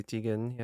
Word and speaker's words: Teigen: I think it's Teigen. – Yeah. Chrissy Teigen.